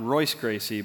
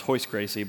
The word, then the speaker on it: Royce Gracie: One of the most famous Brazilian jiu-jitsu fighters is not Royce Gracie, but his brother, which is pronounced Hoist Gracie, but Rickson Gracie, pronounced Hickson Gracie.